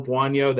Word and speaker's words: Buono: Ernest Johnson, and you had Joel Buono. That is a real person, and that is how you say it.